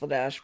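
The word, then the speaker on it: Vladař: I know his name is pronounced Vladash, but we're gonna just call him Vladar.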